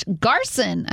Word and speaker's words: Garson: Courtney wants to wish Garson a happy birthday. She said, pronounced like Carson, but with a G.